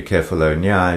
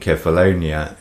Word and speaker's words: Kefalonia: Incidentally, Kefalonia is the Greek pronunciation and Cephalonia is the English, much like the correct Spanish pronunciation of Ibiza as opposed to the English Ibiza.